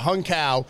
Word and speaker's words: Hung Cao: I said it right, too. I could have said it wrong, but yeah, Hung Cao.